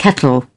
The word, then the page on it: kettle: British English